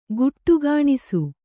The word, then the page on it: ಖೋಡಿಗಾಣಿಸು: khōḍigāṇisu